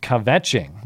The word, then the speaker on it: kvetching: And Cameron says, that word I wrote is pronounced kvetching, which means whining or complaining.